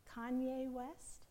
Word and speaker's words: Kanye West: Kanye West.